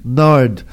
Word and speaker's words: Nared: Nard. – Nard it is. – Yes. – And I knew I had it right, but I just wanted to double check. It's spelled N-A-R-E-D, but it's pronounced Nard.